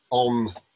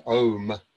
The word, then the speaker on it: om: And it's not om as in bomb, it's pronounced more like om as in home.